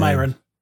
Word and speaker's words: Myron: And it's a quote from Myron. That's how you pronounce his name, right? Myron Larrabee, Sinbad's character. – Yes, and Myron.